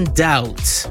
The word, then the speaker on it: doubt: The B in doubt. – Doubt.